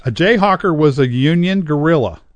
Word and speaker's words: guerrilla: The Spanish pronunciation, it was a guerrilla.